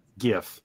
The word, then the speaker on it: gif: Around here, sir, we pronounce it the correct way, gif.